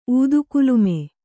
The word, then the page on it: ಊದುಕುಲುಮೆ: ūdukulume